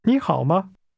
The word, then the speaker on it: 你好: 你好。你好。 – This word for you is pronounced with a rising tone.